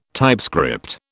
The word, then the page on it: typescript: British English